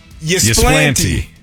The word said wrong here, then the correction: Ypsilanti: It's Ypsilanti. – I need to see how it's spelled again. I've always called it Ysplanti.